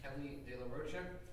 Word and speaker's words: Kelly De La Rocha: Our next writer reader up is Kelly De La Rocha.